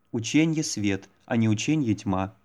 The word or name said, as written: ученье свет